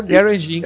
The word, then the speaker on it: Garage Inc: Fez o Garage Inc. Não sei se a é pronúncia tá certa. – Garage, é, é, é Garage Inc.